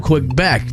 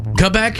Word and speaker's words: Quebec: Namely, the Hudson Bay area and parts of Quebec. There's less gravity there than in other parts of the world. – Quebec? – Mm-hmm. – That's how they say it.